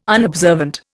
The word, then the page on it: unobservant: Indian